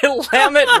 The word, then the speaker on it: Willamette: Willamette rhymes with damn it.